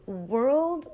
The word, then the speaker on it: world: Now, one thing to remember is that they both have the wr sound. So wr, and then world, we want to make sure that you pronounce the L. World, world, world. Now the word word, we have no L sound. It is word, word. So let's listen to the difference. World, word, world, word.